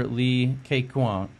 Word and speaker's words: Keqiang: including Mr. Xi and Premier Li Keqiang, can you pronounce Premier Li's last name? – Li Keqiang.